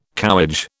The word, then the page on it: cowhage: British English